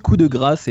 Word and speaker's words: coup de grâce: Et donc voilà, je prononce coup de grâce et bien sûr les Américains disent coup de grâce. – Oui, tu le prononces très bien toi.